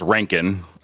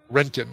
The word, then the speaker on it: Röntgen: And it's pronounced Rankin. – Rankin.